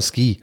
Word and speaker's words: Suki: Und das U spricht man aber im Japanischen nicht aus von Suki. Deswegen sagt Sean Connery auf Deutsch mindestens zweimal Ski.